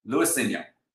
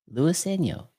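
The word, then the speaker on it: Luiseño: So you say Luis and then Eno. Luiseno. – Luiseno. There you go. – Not Luiseno. There's no swim. Luiseno. Ah, Luiseno.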